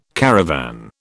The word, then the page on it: caravan: British English